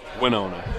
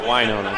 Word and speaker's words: Winona: Winona, not Winona. I was told the other day they win, they don't whine.